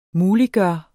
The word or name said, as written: MULIGGJORT